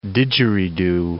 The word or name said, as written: didgeridoo